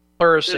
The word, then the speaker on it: pleurisy: And then when she gets sick from pleurisis, if I'm pronouncing it correctly? – Pleurisy. – It's an inflammation of the pleural membranes, correct? – Yeah, pleurisy, yeah.